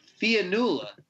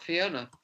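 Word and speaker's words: Fionnula: Actually I think I pronounced it wrong. Fianula. – Sure. – It's not Fiona.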